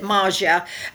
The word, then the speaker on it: Maria: And then the other two girls, it's spelled Maria, but she pronounces it Maja.